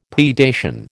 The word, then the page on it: predation: American English